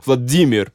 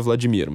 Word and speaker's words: Vladimir: Portanto, é Vladimir Putin, não é Vladimir, mas enfim.